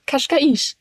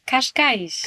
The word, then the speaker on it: Cascais: Kashkaisch. Kashkaisch.